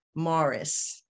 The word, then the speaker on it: Morris: It's G-O-D-W-Y-N, Godwyn Morris, M-O-R-R-I-S.